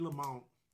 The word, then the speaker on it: Lamont: Freddie Lamont. You pronounce the T or not? – Oh, definitely pronounce the T.